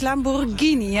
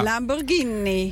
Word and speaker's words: Lamborghini: Ja, nee, maar nu zeg jij het ook verkeerd, want dat zegt Dirk tegen mij. Het is Lamborghini, hè? – Ja, Lamborghini.